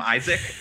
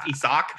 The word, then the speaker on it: Isak: never corrected me when I called him Isaac and like did not let anyone know that his name is pronounced Isak until he was with the Wizards.